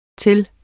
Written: til

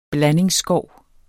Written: blandingsskov

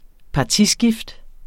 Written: partiskift

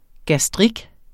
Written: gastrik

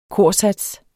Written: korsats

